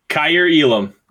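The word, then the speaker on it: Kaiir Elam: Kyer Elam, is that how you pronounce his name? – Kyer Elam, great pick for them.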